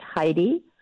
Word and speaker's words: Hydee: My name is spelled Hydee. So that's Heidi, and then Feldstein, Feldstein Soto, Soto.